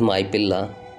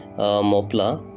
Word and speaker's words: Mappila: for this reason, they were also called as Mappila, which literally meaning is son-in-law. Uh, in English, uh, this word was uh, wrongly pronounced, and people used to call mopla.